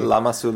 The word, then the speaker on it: Lamassu: Did you have that you were driving past Trafalgar Square and suddenly you see that creature that is called Lamassu? – Yeah. – I pronounce it correctly. – Yeah, Lamassu.